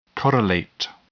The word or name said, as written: correlate